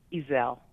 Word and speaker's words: Easel: Easel. – Easel. Gotcha. – Easel. – All right. I'm saying it like a thing you put a painting on, easel.